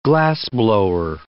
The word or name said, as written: glassblower